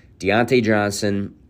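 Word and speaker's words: Diontae Johnson: So one more time, saying it correctly, Deontay Johnson ran the most routes of any wide receiver in the NFL.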